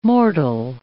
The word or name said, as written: mortal